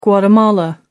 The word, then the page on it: Guatemala: American English